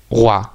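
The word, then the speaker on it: Roi: En plan, el título de la canción se llama R-O-I. Y se pronuncia algo así como Gua.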